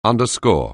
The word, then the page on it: underscore: anglais